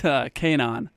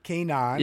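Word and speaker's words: K'naan: It's Kanan. – Kanan.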